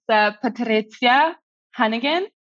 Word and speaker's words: Patrycja Hannagan: Yeah, close. It's difficult for everyone. It's Patrycja Hannagan.